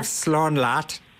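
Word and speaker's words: slán leat: Yeah, that's correct, and then you have slán lat. Which is goodbye, and you say rhymes with cat, so it's foolproof, really.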